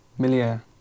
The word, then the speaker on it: Méliès: How did you pronounce it? – Méliès. – Oh, okay, all right, we'll go with that. – Let's go with my French accent.